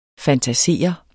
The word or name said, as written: FANTASERET